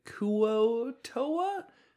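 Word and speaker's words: Kuo-toa: This week's monsters are the Koa Toa. Oh, I'm sorry, the Kuo Toa? Help me out here. – Yeah, Kuo Toa.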